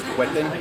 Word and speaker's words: Whetten: Sí, mi nombre, primer nombre es Conal, se escribe C-O-N-A-L, apellido es Huetten, W-H-E-T-T-E-N.